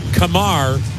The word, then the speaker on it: Kumar: Kamar Rocker, you spell his first name, K-U-M-A-R. You heard his name pronounced a lot of times, Kumar, but that's not right. The pitch, fisted back foul, 1-2 the count. His preferred pronunciation is Kamar Rocker.